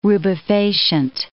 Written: rubefacient

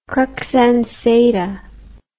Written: crux ansata

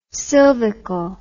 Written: silvical

